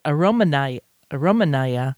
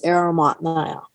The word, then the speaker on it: Arimatnaya: I was pronouncing it as Aromanaya, but that's not correct. – I had someone who volunteered for a day or so who spoke Russian, and she told me it's Arimatnaya.